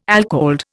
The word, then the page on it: alcalde: Indian